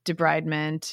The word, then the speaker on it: debridement: She pronounced debridement as French. – Mm. Debridement? – Yeah, it was jarring because... – That is interesting. I've never heard it pronounced like that. I've always heard it, you know, debriding, debridement, you know, and it was like debridement.